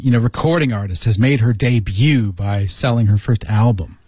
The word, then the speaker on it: debut: 注意这里有个单词、嗯、啊不知道大家知不知道 ,debut, 读音是、 yeah. debut. – debut. – 对对对对。